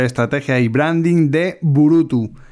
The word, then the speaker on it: Burutü: ¿O cómo lo pronuncia Efectivamente, lo has dicho muy bien, es Burutu. Eh, tiene eh, la dificultad de pronunciación que tiene una diéresis al final, dos puntos sobre el au.